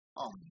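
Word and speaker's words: em: E grace note on low G is spelt the same way as the plain one, E-M, and it's pronounced um.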